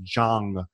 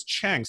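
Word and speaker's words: Jahng: They came over, and most people with the Korean name, it's actually pronounced Jang, and with an open aspiration, right? – Yeah. – Okay. But most people romanized it as Chang, Chang, which a lot of Chinese families do.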